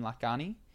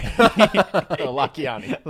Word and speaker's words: Lakhiani: So then the other thing that this made me think, I watched an interview yesterday with Mind Valley guy Vishen Lakhiani. – Yeah, that's not how you pronounce it, but go ahead. – Sorry. – Lakhiani.